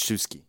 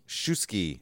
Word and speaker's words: Shuisky: I think it's pronounced Shuisky. – Shuisky. Mm-hmm. Alexander Gorbaty Shuisky. Like whiskey, but with a sh.